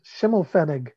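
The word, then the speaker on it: Schimmelfennig: Schimmelfenning.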